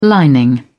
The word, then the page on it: lining: British English